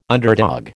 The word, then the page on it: underdog: American English